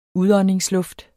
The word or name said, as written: udåndingsluft